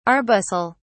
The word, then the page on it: arbuscle: British English